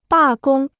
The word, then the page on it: 罢工: bà gōng